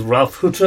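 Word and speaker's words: Ralf Hütter: Is that last week we put in an interview with Ralph Hutter of Kraftwerk.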